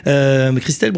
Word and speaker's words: Krystel: Christelle Bruno, ça s'écrit avec un K, ouais. K-R-Y-S-T-E-L. Voilà, c'est pas la prononciation française, c'était...